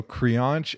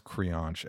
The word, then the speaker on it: Creonte: So Crianche, it's actually spelled C-R-E-O-N-T-E, but it's pronounced Crianche.